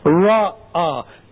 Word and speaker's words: Ra'ah: Okay, maybe you can think of sheep here like, Ba'ah. Ra'ah.